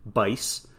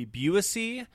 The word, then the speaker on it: Buice: His co-host did have the correct pronunciation, Bice.